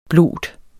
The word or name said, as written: blot